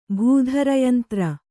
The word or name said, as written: ಭೂಧರಯಂತ್ರ